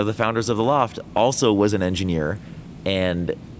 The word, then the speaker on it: L0pht: So Loft Heavy Industries, that's spelled L0PHT, was the Boston equivalent of Cult of the Dead Cow.